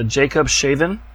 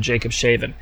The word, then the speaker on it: Jakobshavn: It's spelled J-A-K-O-B-S-H-A-V-N, but, you know, it's Greenland. It's like Norwegian talk. I don't know how they pronounce it, but this is called Jacob Shaven, and it's the fastest moving glacier in the world.